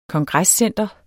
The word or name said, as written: kongrescentre